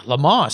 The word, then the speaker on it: Le Mans: And yeah, Le Mans sounds pretty cool, huh? But it kind of rhymes with lemons.